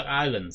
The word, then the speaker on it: island: 其实呢啲又系边个权威边个 popular 就跟咗噶啦，即系冇话啱唔啱嘅，即系唔可以讲得啱唔啱。譬如 Island 嗰个字究竟个发音系咪我哋用一个普通嘅发音，又一定 Island IS 嗰个字系咪咁读嘅咧？可以得 Island 先系咁样读嘅啫嘛，冇咗 S 音，可能系拉丁语本身，即系就系呢个咁。